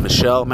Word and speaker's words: Michele: Michelle Masiello, spelled M-I-C-H-E-L-E, and my last name is M-A-S-I-E-L-L-O, and I'm also put a few things on that Instagram.